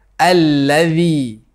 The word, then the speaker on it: الَّذِي: Stick out the tip of the tongue and put it under your two front teeth when pronouncing ذَل in the word أَلَّذِي. – Not using the letter زَي.